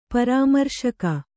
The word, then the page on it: ಪರಾಮರ್ಶಕ: parāmarśaka